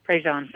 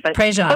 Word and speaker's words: Prejean: Sister Helen Prejean. Did I say that right? – Prejean. – Prejean. – Close enough.